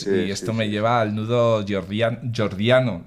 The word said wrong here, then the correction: gordiano: ¿lo he dicho bien? – Gordiano, eso. Gordiano.